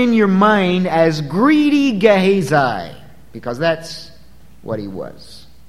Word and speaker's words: Gehazi: Now, that's the way it's pronounced to the best of my ability. I've checked that out. And there are probably other ways to pronounce it. That's not the way you would pronounce it in Hebrew, but I think in English that's probably close enough, all right? G-E-H-A-Z-I, Gehazi, not one of your more famous Old Testament characters.